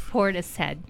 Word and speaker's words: Portishead: Who's checking it's Portishead.